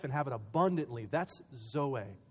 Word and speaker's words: zoe: is a word zoe, and it's spelled Z-O-E, zoe.